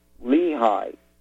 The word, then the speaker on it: Lehigh: The company is called Lehigh. It's spelled L-E-H-I-G-H. That's Lima, Echo, Hotel, India, Golf, Hotel, Defense.